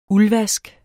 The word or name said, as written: uldvask